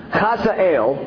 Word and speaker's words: Hazael: So the king said to Hazael, and that's actually how you say it,